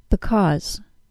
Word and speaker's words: because: To stress because, the second syllable is pronounced with the AW sound. Aw, because. Can you hear the AW sound, aw, in because?